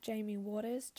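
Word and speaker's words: Jaimye Waters: Follow me on Instagram at Jamie Waters, JAIMYEWATERS.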